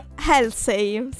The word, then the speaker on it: Halsey: E Halsey, si pronuncia così.